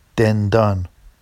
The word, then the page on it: dendɑn: Vafsi